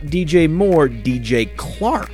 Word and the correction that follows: Chark: It's actually pronounced DJ Chark. – That's in the outtakes. Clark. Chark. DJ Chark.